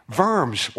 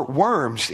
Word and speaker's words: Worms: including Worms, or Worms, if you want to just say it like good English people do, in Germany, which is where Luther stood and famously defended himself in front of a group of people.